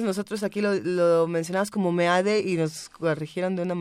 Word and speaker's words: Meade: MID, el apellido es español y se pronuncia Meade.